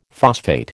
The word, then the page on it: phosphate: American English